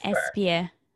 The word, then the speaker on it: Espir: Am I saying that right, Espir? – Deanna Espir.